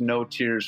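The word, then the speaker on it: Notiers: So we call ourselves No Tears Frontiers. That's N-O-T-I-E-R-S Frontiers, because it rhymes.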